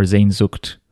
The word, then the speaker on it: Sehnsucht: Uh, you pronounce S's in German like a Z, right? Did I do that right? Yes. Like Z, like for that. Well, could you spell it? – S E H N S U C H T. Yeah, like Sehnsucht. Sehnsucht.